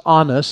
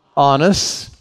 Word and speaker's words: Annas: They take him first to the house of Annas. That's how you pronounce it. Everybody say that with me. Annas.